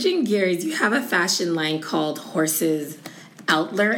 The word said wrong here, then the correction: Atelier: Am I pronouncing that? – Yeah. – Atelier.